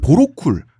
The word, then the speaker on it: 보로쿨: 그리고 보로쿨, 그러니까 보로올이라고도 합니다. 그러니까 표기법에 따라 발음이 다른 같은 사람이에요.